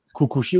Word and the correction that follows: Kikuchi: qui euh, pardon, si je le prononce bien. Kikuchi, ouais.